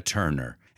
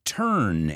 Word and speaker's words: Turner: And I want to look at the pronunciation of her last name because it's got the word turn in it. I've heard this word mispronounced so many times. It's not turn, it's turn. I want you to imagine the U as an E, Turn.